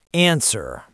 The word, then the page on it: answer: American English